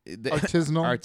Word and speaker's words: artisanal: Artisanal? – Artisan. Artisanal. – Yeah, artisanal. – Artisanal. – Artisanal. You got to have an extra syllable.